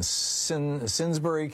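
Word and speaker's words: Simsbury: Jason in Sinsbury, Connecticut. Am I saying it right, Jason? – Hey, Tom, how you doing? – What's up? – Yep, you got it.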